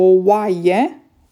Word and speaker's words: oaie: o oaie, ok, acum venim la niște cuvinte grele în română, o oaie, două oi. Oi, oi, oi, săracii. E greu de pronunțat, știu. Oaie, oaie. Se scrie O-A-I-E. Patru vocale.